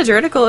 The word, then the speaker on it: juridical: Secondly, the comic has several examples and plot points of how Detect Evil can go wrong, and the juridical implications. Did I say that right? – Juridical? – Yeah, juridical implications of this.